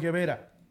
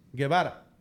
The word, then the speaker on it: Guevara: ¿Como Che Guevara? – No, como Che Guevara, no. Primero no es Guevara. Es Guevara.